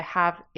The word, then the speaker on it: ha: Spelt H-A, but the H is silent, as it always is in Italian.